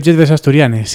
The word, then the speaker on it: Lletres Asturianes: También tienen confirmado que habrá una exposición en la Semana de les Lletres Asturianes. ¿He pronunciado bien con mi acentazo? – Semana de Lletres Asturianes, sí, sí, está bien dicho.